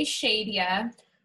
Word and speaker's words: Sheedia: So I'm Hey Shadia on Facebook and Instagram. And my name is spelled S-H-E-E-D-I-A. So Hey Shadia on both platforms.